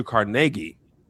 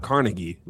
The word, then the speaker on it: Carnegie: I was watching History Channel when I was in Atlanta a few weeks ago about apparently his name is pronounced Andrew Carnegie. It's not Carnegie like I thought all these years.